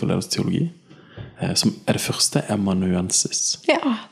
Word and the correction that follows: amanuensis: Er det, er det rett uttalt? Jeg synes ja, alltid det var liksom vanskelig. Første amanuensis. Ja.